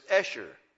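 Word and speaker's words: Esher (אשר): So she called his name Asher, or actually the Hebrew is Esher. Esher, they think. Nobody was back there. They weren't hearing it pronounced back then. So that's the way they would pronounce it now in Hebrew is Esher.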